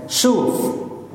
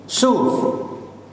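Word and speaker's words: Shuv (שוב): The Hebrew word for repentance is very simple. Turn. You can even learn this Hebrew word this morning. Shuv. Shuv. The Hebrew word for repentance is turn. Shuv.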